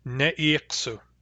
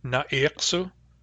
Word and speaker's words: ne'iksu: Ne'iksu, with a short e in the first syllable. What we see here is umlaut. In Central and Northern, it's a short a, na'iksu, na'iksu, because those dialects generally do not undergo umlaut. Barkly Sound dialects are umlauting dialects, so ne'iksu has an e.